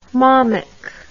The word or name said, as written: mommick